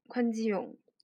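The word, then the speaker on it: 宽 基 勇 (권지용): 对，那么这个权志龙呢，读作宽基勇，宽基勇，权志龙。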